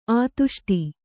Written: ಆದಿಷ್ಟ

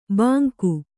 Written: ಬಂಗು

